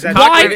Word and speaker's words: Cockburn: Yes, it is Cockburn. It's not Coburn.